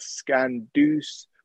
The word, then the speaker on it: Skandouz: You can follow me at Skandouze, which is spelled SKANDOUZ.